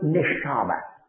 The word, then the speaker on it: neshama: Now this word, I'll give you the English pronunciation of the word, is the word neshama.